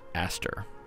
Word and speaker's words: ASTR: You can omit the O because they are strictly A-S-T-R, yet it's pronounced Aster.